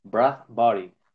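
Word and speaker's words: Bradbury: Encima dije mal el nombre, weón. Dije Badri, Es Brad Body.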